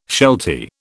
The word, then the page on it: sheltie: British English